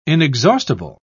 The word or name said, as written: inexhaustible